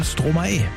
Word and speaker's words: Stromae: Na, ich weiß jetzt, wie man den ausspricht. Hab den mir letzte Woche geschrieben, hab gesagt, Mensch, der hat doch einfach Maestro umgedreht, die Silben, und deswegen heißt der Stromae.